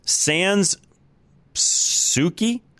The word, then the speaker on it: Sans Souci: Boulder County's Sans Souci, I think I'm saying that right, S-O-U-C-I, Souci, Sans Souci, I don't know how to pronounce it, mobile home park, jumped at Colorado's opportunity to purchase.